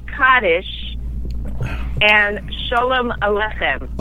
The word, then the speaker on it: Kaddish: And he says it's Kaddish. So that is the Israeli way of pronouncing it.